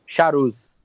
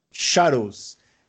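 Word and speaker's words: Charouz: Como é que pronuncia o, o nome Char dessa? Charus. Charus.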